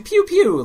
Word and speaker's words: Pew Pew: So I downloaded, for $3, I downloaded Poo Poo, the alien. – Okay. – Pew Pew or whatever. I think it's actually Pew Pew, like laser gun is how it's pronounced.